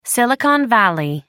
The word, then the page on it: silicon valley: British English